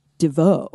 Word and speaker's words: Devo: It was actually supposed to be pronounced Devo. Like, that's how they always say it in interviews, which is super weird.